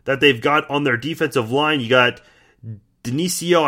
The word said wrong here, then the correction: Denico: I hope I pronounced that correctly. I think it's Denico Autry.